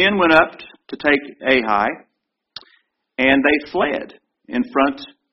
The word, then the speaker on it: Ai: Ai. Ai is how you pronounce it.